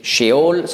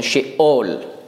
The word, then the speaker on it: Sheol: Das hebräische Wort Sheol, das kleine Strichlein dazwischen, zeigt einfach, wie man es aussprechen muss. Nicht Sheol, sondern Sheol, mit Stimmabsatz.